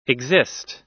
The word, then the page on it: exist: American English